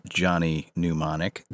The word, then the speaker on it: Mnemonic: Today, we are talking about the film Johnny Mnemonic. The M is silent. And the first M,